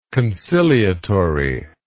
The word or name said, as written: conciliatoriness